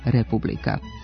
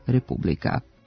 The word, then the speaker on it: republika: Navedeni pridevi nisu usamljeni primjeri nenormiranog prenošenja akcenta na prvi slog, već istu pojavu prepoznajemo i u izgovoru imenica Amerika, politika, republika.